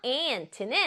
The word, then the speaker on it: aunt: aunt는 에 소리 나고 유 소리 안 나요? 트. 개미랑 발음이 똑같아요. aunt.